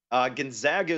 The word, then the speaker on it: Gonzaga: Is it Gonzaga or Gonzaga? – Gonzaga is what I've been told.